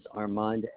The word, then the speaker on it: Armand: My first name is Armand, A-R, M as in mother, A, N as in Nora, D as in David.